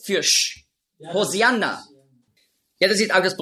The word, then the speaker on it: Hosianna: Hosianna, ja, ist einfach die griechische Aussprache von Hoshi'anna. Hosianna, das ist die griechische Aussprache. Weil die Griechen, die haben im Alphabet kein Sch-Laut. Nurz.